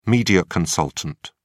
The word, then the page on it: media consultant: British English